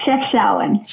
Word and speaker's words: Chefchaouen: Uh, is Chef Showen correct? – Yes, Chef Showen. Chef yes, Showen. Very. Yep.